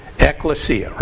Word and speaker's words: ekklesia: The Greek word we pronounce, and you've all heard it, ekklesia, right?